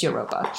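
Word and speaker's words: Jurupa: It's spelled Jaruba, but I think it's Europa.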